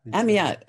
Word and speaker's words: Amyot: Well, I would say Amyot. – Amyot.